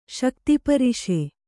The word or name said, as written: ಶಕ್ತಿಪರಿಷೆ